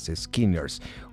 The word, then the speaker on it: SKNNRS: los Skinners de aquí de Aguascalientes, échenles una oreja, se escriben SKNNRS, Skinners,